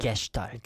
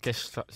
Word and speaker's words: Gestalt: Gestalt. – Gestalt, yes.